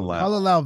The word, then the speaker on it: Kalalau: Kalalau Valley.